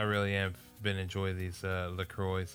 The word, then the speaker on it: LaCroix: They say on their website, LaCroix rhymes with enjoy.